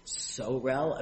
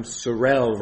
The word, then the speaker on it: sorel: So I think that the way that appellant pronounced it is they said that their term sorel rhymes with morel, like the mushroom.